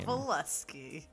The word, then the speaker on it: Kowalski: Kowalski. – It's Polish, I'm assuming, right? – Nothing like that, but yes. Very Polish. – Because the W is pronounced as a V.